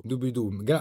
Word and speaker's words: Dubidù: Dubidù, bravo, perché non è facile e la pronuncia spesso sbagliano tutti.